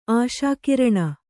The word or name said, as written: ಆಶಾಕಿರಣ